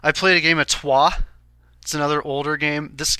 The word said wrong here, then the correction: Troyes: How do you spell that? – T-R-O-Y-E-S. – Oh, that's Troyes. Sorry, you pronounced it wrong. – Troyes. – Troyes. – Troyes, okay.